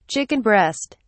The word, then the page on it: chicken breast: British English